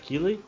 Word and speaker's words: Keighley: Fizeram piada com a pronúncia do nome dele, então não é só os brasileiros. Que é Jeff mesmo. Jeff Killey.